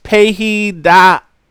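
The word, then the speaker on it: paidagogos (παιδαγωγός): And it is pronounced paidagogos.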